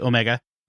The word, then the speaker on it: Omega: Omega, or as Americans say, Omega,